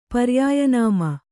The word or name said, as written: ಪರ್ಯಾಯನಾಮ